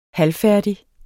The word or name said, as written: halvfærdig